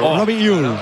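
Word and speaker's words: Robbie Ure: A ver, el club pasó incluso un audio, lo ha pasado. Y en concreto sería Robi Ur. Robi Ur.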